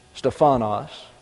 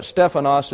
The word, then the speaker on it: Stephanos: The correct emphasis is on the first syllable in the Greek. Although in our language, English, we tend to emphasize the penultimate syllable, Stephanos. But Stephanos is the correct pronunciation, and translated it means crowns.